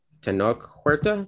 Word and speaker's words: Tenoch Huerta: There was a lot of talk online about like, yay, representation, because uh, the guy playing Namor, uh, Tenoch, Tenoch Huerta, I think.